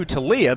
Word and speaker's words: Toglia: His last name is pronounced T-O-G-L-I-A, but the G is silent. Making his way into the batter's box. And the first pitch to Talia, misses for ball one.